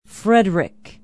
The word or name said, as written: frederic